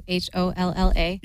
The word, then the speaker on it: holla: Holla. That's how I know it's cool because you don't pronounce the R at the end.